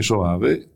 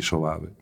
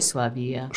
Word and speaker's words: Soave: Come si dice? Non si dice Soavia.